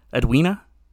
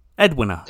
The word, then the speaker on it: Edwina: I don't think you're supposed to put that much emphasis on the we part of the name. Edwina? Edwina. – Edwina.